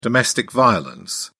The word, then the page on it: domestic violence: British English